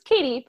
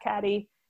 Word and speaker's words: Cady: And her name is Katie, but it's spelled C-A-D-Y. And she comments that like, oh, it's Katie, not Caddy.